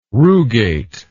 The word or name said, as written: rugate